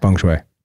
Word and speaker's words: Feng Shui: Feng Shui.